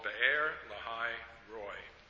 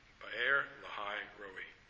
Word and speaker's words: Be'er Lahai Roi: That is why the well was called Be'er Lahai Roy. It is still there between Kadesh and Bered. I should pronounce that Roi, if I'm doing just like the Hebrew. Be'er Lahai Roi.